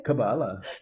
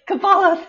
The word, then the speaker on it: Kabbalah: I can't even say, can you pronounce it for me? I'm so terrible with this. Kabbalistic? – No, of course. – How do you say it? – Kabbalah. – Kabbalah, thank you, Kabbalah.